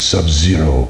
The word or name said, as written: sub zero